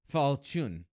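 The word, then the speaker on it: Falchion: It's pronounced Fall Chun because he's Kamen Rider Fall Chun, but it's just like spelled weird. – Okay. – Huh. But I guess it's because it's French or whatever. – Oh. – So it's Fall, like Fall Chun, I guess.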